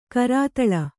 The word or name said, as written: ಕರತಾಲ